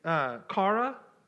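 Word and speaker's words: kara (χαρά): So joy in this portion of scripture in the Greek is kara, that's how it's pronounced. It's C-H-A-R-A.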